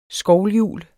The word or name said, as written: SKOVLHJUL